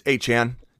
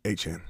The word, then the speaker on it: Achane: I tried to do some paid for Achane, like straight up. But that if you're going to own him, start saying his name right, though. Thomas is Achane.